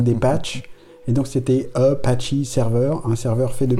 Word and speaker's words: Apache: En fait, c'était Apache, c'est pas tellement le nom des Indiens, c'est que c'est devenu Apache en anglais, ça se prononce Apache.